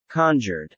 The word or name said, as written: conjured